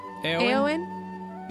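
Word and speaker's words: Éowyn: How do you say her name? – Eowyn. – You sure? – Uh-huh. That's what I've always Eowyn. – Said. – Eowyn. – Is it? – Eowyn. – I've always said Eowyn, because that's how they say it in the movies.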